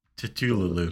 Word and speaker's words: Cthulhu: How the heck do you pronounce that? Cthulhu. – Yes. – That's probably it. Cthulhu. – Yes, there you go.